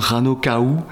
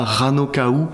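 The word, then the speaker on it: Rano Kau: Et en plus de ça, c'est une langue que j'aime beaucoup, le Rapa Nui, et je trouvais que ça sonnait bien Rano Kaou. D'accord. Ça s'écrit K-A-U, donc comme en polynésien, c'est-à-dire que le U se prononce OU.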